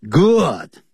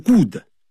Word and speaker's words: good: Good，好，非常的棒，不要念成 good。 Or good，跟书本 book 的发音一样，要念成 book，不要念成 book。